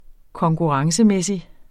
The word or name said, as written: konkurrencemæssig